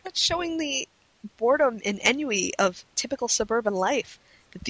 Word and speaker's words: ennui: Ennui. If you're going to sound like a douche and speak French, at least pronounce it Frenchly.